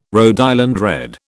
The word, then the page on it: Rhode Island Red: British English